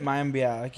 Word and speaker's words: Mayim Bialik: It's Mayim Bialik, right? – Mayim Bialik, yeah.